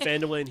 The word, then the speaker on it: Phandalin: Is it Phandalin? – That's how we're pronouncing it right now. – Yeah, in Phandalin.